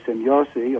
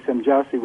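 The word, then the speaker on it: Semjase: The Swiss say Semjassi, of course. I say Semjassi with a hard J now,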